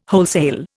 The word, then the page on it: wholesale: Indian